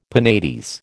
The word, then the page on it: penates: American English